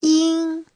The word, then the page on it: yin: yin1